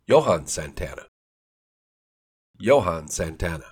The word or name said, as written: Johan Santana